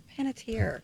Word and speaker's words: Panettiere: Panettiere.